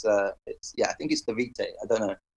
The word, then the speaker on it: DeWitte: I've heard her say it, so it's like DeWitte, yeah. It's not how I said it, basically, anyway.